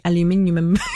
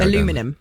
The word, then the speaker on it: aluminium: Aluminium. – Aluminium. – Yes. – But why do the American... Oh, well, we could go aluminum. – Down. Aluminum.